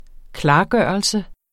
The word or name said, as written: klargørelse